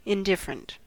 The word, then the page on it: indifferent: American English